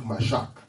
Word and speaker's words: mashach: Now the word he anointed is the word in the Greek mashak. That's the word in the Greek mashak. Mashak. Mashak. I'll spell it for you. M-A-S-H-A-C-H.